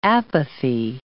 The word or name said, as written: apathy